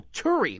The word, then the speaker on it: Turi: Terry. Terry. Hi, Terry. – It's Turi. – Oh, Turi. What, how do you spell that? – T-U-R-I. – T-U-R-I.